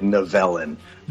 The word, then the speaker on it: Nivellen: Yeah, the beast's name is Nivellen, I believe if I'm saying that right. – Uh, he pronounced it Novellin.